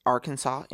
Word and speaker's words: Arkansas: In Arkansas, it is strictly prohibited to pronounce Arkansas incorrectly. Arkansas.